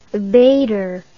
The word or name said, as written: abator